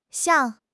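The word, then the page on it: xiang4: tone 4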